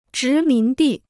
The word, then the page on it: 殖民地: zhí mín dì